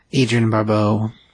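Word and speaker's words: Adrienne Barbeau: Agina Barbeau, I said? – Agina Barbeau. – That's what I said, yep. – That's not how you say it. – That's a D, that's not a G.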